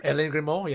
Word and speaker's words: Aline Grimaud: Aline Grimaud.